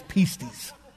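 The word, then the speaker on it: pistis (πίστις): Now you might go, that's not the way it's pronounced. I Googled it and listened to it 100 times. It's pistis. Stand bold on that.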